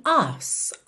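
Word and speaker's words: arse: É, essa expressão, to dance your ass off, ass aqui é uma palavra do inglês britânico, que é A-R-S-E. Como eles não costumam enrolar o R, você não tem tanto aquela pronúncia ars, ars, e sim ass, ass, ok?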